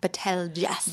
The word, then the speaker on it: Betelgeuse: Okay, but it's not spelled like it's spelled like Betelgeuse.